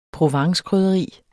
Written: provencekrydderi

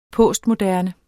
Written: postmoderne

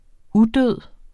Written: udød

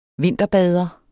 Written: vinterbader

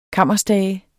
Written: kammerstage

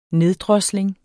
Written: neddrosling

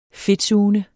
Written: fedtsugende

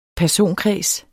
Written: personkreds

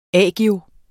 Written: agio

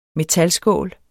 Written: metalskål

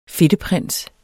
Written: FEDTEPRINSER

